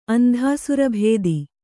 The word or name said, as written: ಅಂಧಾಸುರಭೇದಿ